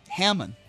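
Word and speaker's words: Hammon: Becky Hammond is a great coach. Did I pronounce that right? – There it is, right there. No D on the end.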